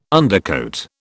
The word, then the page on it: undercoat: British English